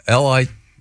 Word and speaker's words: Lititz: Yeah, it's Lititz. – Pretty sure. – You doubt Lititz over Lititz? – I'd run for mayor just to be. I'm the mayor of Lititz, Pennsylvania. What is it? – Lititz.